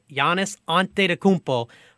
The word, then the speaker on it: Antetokounmpo: Giannis Antetokounmpo, yeah, I said it right, Giannis Antetokounmpo finally gets in to the NBA championship,